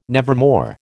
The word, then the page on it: nevermore: American English